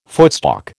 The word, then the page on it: footstalk: American English